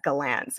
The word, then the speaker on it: Gallant: But I think that Gerard Gallant is how we're pronouncing it now, by the way, guys. That's a new thing. Apparently he pronounces it as Gallant.